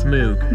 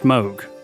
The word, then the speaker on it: Moog: Most of you have probably heard his name before, although you might have heard it pronounced as Moog. Moog. Moog. Moog. But it's definitely pronounced Moog. Trust us on this one.